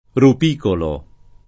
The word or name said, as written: rupicolo